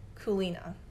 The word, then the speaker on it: Culina: And I want to talk about Kulina. It's spelled C-U-L-I-N-A,